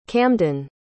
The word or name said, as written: camdin